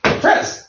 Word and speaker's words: fraise: Fraise.